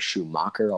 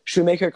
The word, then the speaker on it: Shoemaker: He's one of the few that have their name spelled Shoemaker and pronounce it Shoemaker. A lot of people are Schumacher, all sorts of garbage.